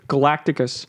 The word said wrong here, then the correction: Galactus: Galactus? – Yeah, Galactus. I'm sorry.